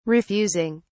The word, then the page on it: refusing: British English